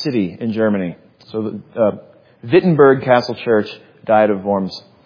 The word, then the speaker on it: Wittenberg: And so, yes, when you see a German name and there's a W there, it's not Wittenberg, it's Wittenberg. They pronounce the W's as V's.